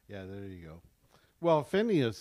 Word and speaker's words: Phineas: So when so what happened is that basically the sons of Eli, and I can't pronounce it's Phineas and Hophni. – Yeah, Phineas and Hophni.